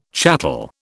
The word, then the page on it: chattel: British English